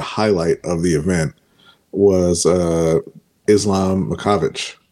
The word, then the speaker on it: Makhachev: Islam Makhachev. – Makhachev. – Okay. Man. Islam Makhachev. Okay. – Makhachev. So for me, I think that the kind of the highlight of the event was Islam Makhachev, who is...